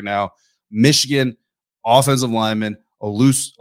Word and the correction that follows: Olusegun Oluwatimi: I already messed it up. Olusegun Oluwatimi.